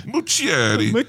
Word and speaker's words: Muschietti: If Andy, you lean into the Italian accent, it's easier to pronounce. – Right. I'm like Chris Pratt in Super Mario. – Right. – Super Italian. Andy Muschietti.